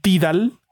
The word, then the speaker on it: Tidal: Eh, hay una aplicación que se llama Tidal, se escribe Tidal, así como suena, T y latina de dedo A L, Tidal o Tidal,